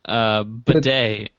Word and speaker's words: bidet: Klaus or Bedelt? – Bidet. – Oh, is that how you say it? – Yeah, it's a bidet.